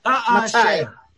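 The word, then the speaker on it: Matai (מתי): In fact, how do you say when in Hebrew? – Matai. – Matai.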